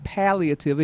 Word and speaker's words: palliative: And he's talking about palliative. And yes, I'm going to pronounce it correctly this time. You got it. – Palliative. – You got it right.